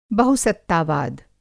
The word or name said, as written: बहुसत्तावाद